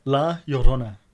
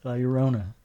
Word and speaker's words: La Llorona: Yeah, La Llorona.